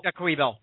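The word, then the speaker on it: Joique Bell: I don't even know how to say his name. Joyke Bell or Jacque Bell? – Jacque Bell, yeah.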